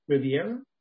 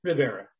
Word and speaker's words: Rivera: The host, Jaime Rivera, I think I said that right, Riviera? No, Rivera.